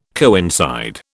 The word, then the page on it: coincide: British English